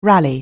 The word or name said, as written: rally